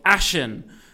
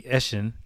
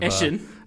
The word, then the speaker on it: Ashen: If anything, it's the second game, because I have talked about on previous episodes a game called Ashen, which was developed by A44, a New Zealand-based studio. – If you pronounced it the way it's meant to be, Ashen, I would have... – Ashen.